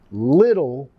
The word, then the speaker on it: little: Vamos a pronunciar la TT, la doble T, como la R suave de español. No decir little, más little. Little.